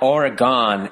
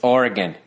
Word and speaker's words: Oregon: They're like, it's not Oregon, it's Oregon. Really? Then why don't you spell it G-I-N?